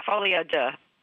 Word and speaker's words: folie à deux: Yeah, folie à deux.